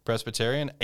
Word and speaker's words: Presbyterian: St. Andrews versus Presbyterian, is that how you pronounce it? – Yeah, Presbyterian.